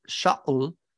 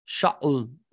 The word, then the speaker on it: Shaul: The name in Hebrew, Shaul, it's an SH. Greeks don't have an SH sound. And then two, it's got a deep glottal stop in there that Greeks don't have. Shaul, you don't have that in Greek. It doesn't sound Greek.